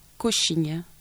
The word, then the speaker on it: Coxinha: ça s'écrit, alors, c'est C-O-X-I-N-H-A, Coxinha.